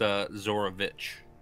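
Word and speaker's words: Zarovich: I think it's pronounced Zorovich. – Oh, Zorovich. My apologies. – You hear a voice? – Didn't know that it was Russian. – It's von Zorovich!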